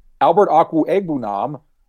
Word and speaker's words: Albert Okwuegbunam: And that's going to come because as we talked about before we started recording, Albert Okwuegbunam, which is how you're supposed to pronounce his name.